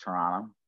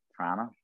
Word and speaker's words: Toronto: Um, we we don't pronounce the second T. It's just Toronto, Toronto, Toronto, something like that.